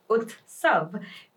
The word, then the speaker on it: UTSAV: that is UTSAV, pronounced as UTSAV, meaning a festival or celebration.